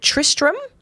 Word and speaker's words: Tristram: His name is Tristram. And yes, I am saying that right. Oh. Shapiro. I do know a Tristram, but I've never seen a Tristram, so there's an R after the T as well on both accounts.